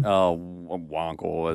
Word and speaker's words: Wankel: Wankel.